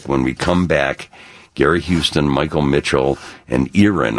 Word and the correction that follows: Aaron: I'm sorry I mispronounced your name, Erin. – It's actually Aaron, so that's all right. – It is? – Yes. – Oh. Well, Gary, yeah, somebody, we all, Erin. We don't care. It's spelled Aaron, and I'm pronouncing it now Aaron.